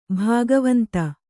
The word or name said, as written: ಭಗವಂತ